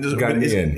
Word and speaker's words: Ghanaian: So Ghanaian. It's the emphasis on the second syllable. Yes.